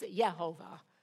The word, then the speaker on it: Jehovah: And I said, you know, what is the correct pronunciation? And she said, well, in English, it would be Jehovah, but in Hebrew, it's Yehovah with a Y.